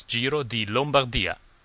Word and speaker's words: Giro di Lombardia: Again, I love your show, and it's Giro di Lombardia.